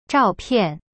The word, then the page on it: 照片: zhao pian